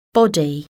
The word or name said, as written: body